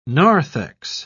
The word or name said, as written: narthex